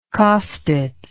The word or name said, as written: costate